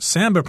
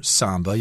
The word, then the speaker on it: samba: And here in Rio, you'll see some elaborate samba performances, or samba. You can pronounce it both ways.